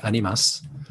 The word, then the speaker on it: Animas: Animas is the Spanish word for souls. That would be pronounced by Spanish speaking people more like animas.